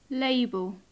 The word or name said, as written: label